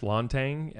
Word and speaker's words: Lanteigne: So on Instagram, it's Lanteigne, L-A-W-N-T-A-N-G. So that's how you pronounce my last name. – Yep. – Lawn like the front lawn and Tang like the juice.